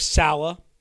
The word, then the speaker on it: Saleh: I'm not sure why they were never able to get Saleh onto the staff, or Saleh, I guess is officially how you pronounce his name. I always call him Saleh. I think that's kind of how it should be, but Saleh is fine.